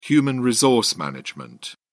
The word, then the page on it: hrm: British English